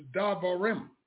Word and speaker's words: Davarim: The name of that book is Davarim. D-A-V-A-R-I-M.